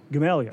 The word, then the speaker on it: Gamaliel: We pronounced it Gamalia. – Gamalia, yes, that's right. It looks like Gamaliel or something like that.